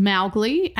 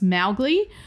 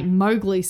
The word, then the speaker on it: Mowgli: So it is meant to be pronounced Mowgli, and Rudyard Kipling had explained that. It's Mao as in cow, so it's Mowgli.